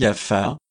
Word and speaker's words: Jaffa: Je sais qu'on prononce en Syrie Jaffa, et Monsieur de Volnay l'écrit ainsi, mais je ne sais point l'arabe.